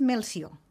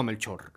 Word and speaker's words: Melcior: El Melchor en català és Melcior.